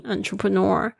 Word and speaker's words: entrepreneur: 因为它后面是 p r e。 N E U R，nor，你还要记它发音。 Entrepreneur 是指企业家、创业家的意思。